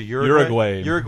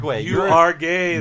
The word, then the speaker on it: Uruguay: How do you say the name of the country? – Uruguay. – You are gay. That's how Homer pronounces it.